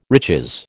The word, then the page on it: riches: American English